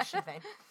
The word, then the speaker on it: Sinn Féin: I had to look up the pronunciation of Sinn Féin to make sure I was getting that right. And I was. – The SH was there. Yeah, Sinn Féin.